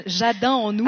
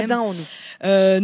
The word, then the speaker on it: Jadin en nous: Sur le titre, vous voulez dire ouais, Jadin à nous. – Cynthia. Alors, donc, le titre, c'est Cynthia. – Je reprononce pour les créolophones, Jadin en nous.